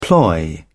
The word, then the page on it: ploy: British English